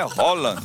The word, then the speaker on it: Haaland: Ele falou: a pronúncia do meu nome é Haaland.